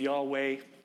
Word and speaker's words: Yahweh: If you didn't know already, the theme of tonight's service is Yahweh, your correct pronunciation of the word, Yahweh, my shepherd.